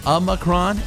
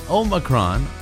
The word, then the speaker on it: Omicron: Officially, almost anything works. Both Omicron and Omicron are fine. The Oxford Dictionary includes Omicron, and in classical Greek, it was pronounced with the second syllable sounding like me.